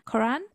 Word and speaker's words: Quran: so in Islam, the Quran, Quran, that's how you say it, right? The Quran yeah.